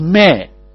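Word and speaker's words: meh (میں): That me in Urdu is pronounced as meh. In Urdu, what we say me in English, in Urdu it's meh.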